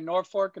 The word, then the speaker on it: Norfolk: I had family in Norfolk until recently. I know that it's not pronounced, you know, it's not pronounced with an L, it's pronounced with an R.